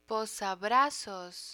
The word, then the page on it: posabrazos: Spanish